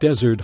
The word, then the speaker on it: desert: On a dark desert highway, cool wind in my hair. – 어두운 사막의 고속도로, 차가운 바람이 내 머리카락을 휘감네. Desert는 사막이죠. 여기에 S가 하나 더 들어가면은 Desert라고 발음하는데요. Desert는 후식입니다.